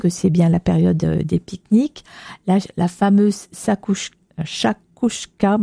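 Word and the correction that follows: chakchouka: moi je sais jamais le dire, c'est très bon, mais difficile à prononcer. – Chachouka, non? – Oui, oui. – C'est chachouka? – Oui, moi j'ai non, c'est ça. Chachouka.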